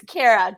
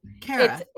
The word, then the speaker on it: Khaira: Really close. It's Kara, just pronounced C-A-R-A, but Kara. It's a lot better. Kara. Yeah, most people call it Korea, Kahira. Kara works perfectly for me.